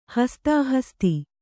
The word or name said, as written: ಹಸ್ತಾಹಸ್ತಿ